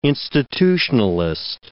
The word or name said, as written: institutionalist